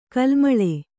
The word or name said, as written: ಕಲುಮಳೆ